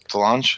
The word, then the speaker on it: DeLonge: And I think that was through Tom DeLong, DeLong, DeLonge. How do you say it? – Correct.